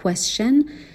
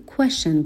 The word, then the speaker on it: question: به جای اینکه بگیم question, خیلی از جاها توی تلفظ آمریکایی question گفته میشه و این تلفظ اشتباه نیست, فقط از تکنیک ادغام یا assimilation استفاده شده.